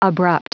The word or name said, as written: abrupt